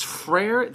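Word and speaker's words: Frère: Frere. – Now, it's Frere. There's not two syllables to Frere.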